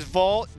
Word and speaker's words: Vogt: Steve is Vogt, the only guy with a silent T in the world to his name.